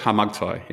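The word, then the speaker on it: Kharmagtai: It's a silent K, so it's Harmattai, yeah.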